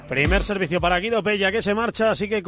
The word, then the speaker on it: Guido Pella: Guido Pella, aunque se escriba con elle. Así es como se pronuncia nuestro rival hoy,